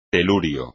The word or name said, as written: telurio